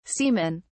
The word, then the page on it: seamen: British English